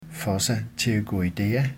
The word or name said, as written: fossa pterygoidea